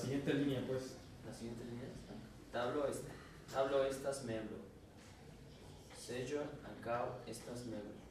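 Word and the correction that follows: ankaŭ: Un solo error en la pronunciación, en el acento? – Ancao. – Ancao. Él mismo, mira, se dio cuenta, él mismo.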